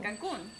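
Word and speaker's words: Cancún: Cancun.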